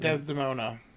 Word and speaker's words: Desdemona: Um, should the player not trigger the uh, evacuation signal before destroying the institute, and then speak to um, ah, I can't say her name, Desmesda? – Oh, uh, mm-hmm. Desdemona. Desdemona, thank you.